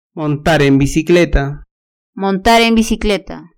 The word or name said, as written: montar en bicicleta